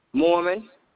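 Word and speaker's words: Morman: Avesta Mormon.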